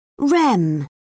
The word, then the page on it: rem: British English